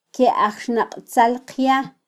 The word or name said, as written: kyeajxnaqtzalqya